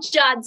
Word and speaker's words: Giada: But it's just the way that you pronounce it, like you're, yes, it's called Giada. It's called Giada.